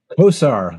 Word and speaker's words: Kosar: Kosar, a bit like Bernie Kosar, who once quarterbacked the Browns.